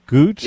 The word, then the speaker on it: Gutt: I can't speak for other towns, but if Anthony wants some killer corn, hit the Goot Family Farm stand. – How do you spell that name? – G-U-T-T. – G-U-T-T. Goot yep.